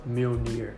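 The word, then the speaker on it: Mjölnir: By the way, it's pronounced Mjolnir. – Mjolnir? Yeah. Okay, there we go.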